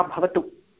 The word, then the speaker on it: भवतः: अत्र पश्यतु महोदय, अत्र उच्चारणं सम्यक्तया वक्तव्यम्. उच्चारणं सम्यक्तया भवतु इत्युक्ते भवतः नाम किम्? भ इति महाप्राण महाप्राणवर्णः.